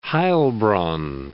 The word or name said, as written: Heilbronn